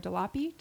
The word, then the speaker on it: DeLappe: So number one, The Wolves from Playwrights Realm, Sarah DeLappe. Did I say her DeLapp. – Name? DeLapp. The E is silent.